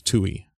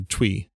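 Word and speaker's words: Thuy: I think her name was Tui Tran. Yeah. And I think that's how it's pronounced. I knew somebody who had the name spelled like that. It's T-H-U-Y, and she was Tui, so, or Tui. But she was in The Crow, where she was like a dominatrix fucking like right hand lady to the main guy.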